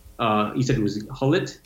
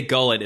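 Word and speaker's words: Gullit: Ruud Hullet. I pronounced that all wrong my entire life. – I think you can say Gullet as well.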